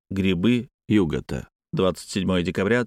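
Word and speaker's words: Yuggoth: В оригинале Лавкрафт так выстраивает стихотворный размер, что ударение в обоих отрывках падает на первый слог «югота».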